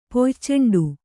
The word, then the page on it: ಪೊಯ್ಚೆಂಡು: poyceṇḍu